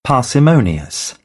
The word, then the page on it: parsimonious: British English; ɑː